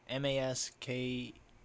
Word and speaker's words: Maskelyne: The guy's name, I'm going to try to pronounce this properly. Jasper Maskelyne. Maskelyne. – Sounds about right.